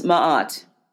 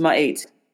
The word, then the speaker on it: Ma'at: Ma'at, which most people pronounce Ma'at, um, but it is, is pronounced Ma'at.